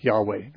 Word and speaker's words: Yahweh: Jehovah, or actually Jehovah is not really a correct pronunciation, more like Yahweh.